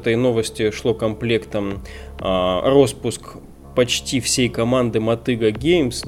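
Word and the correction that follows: Motiga: ну Мотига Геймс правильно читается, mm-hmm.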